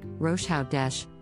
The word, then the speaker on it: Rosh Hodesh: This is a Hebrew word pronounced Rosh Hodesh, which literally means head of the month.